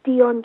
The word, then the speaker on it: Dion: So my website is Dion Johnson. So my Dion is spelled D-I-O-N. Johnson is J-O-H-N-S-O-N.